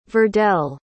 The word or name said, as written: verdell